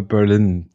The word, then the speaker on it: Berlin: Ich glaube, korrekt ist es Berlin, die Betonung aufs I, aber ich fand es auch nicht schlimm vorher.